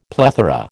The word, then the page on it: plethora: American English